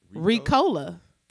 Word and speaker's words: Ricola: Ricola? Is that how you said that? – How do you say it? – Ricola.